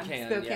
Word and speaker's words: Spokane: You're from, I can't even say it, Spokane. – Spokane.